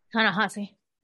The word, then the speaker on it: Ta-Nehisi: And I think it's because of people like Ta-Nehisi Coates. Did I say it right? Ta-Nehisi? – Ta-Nehisi.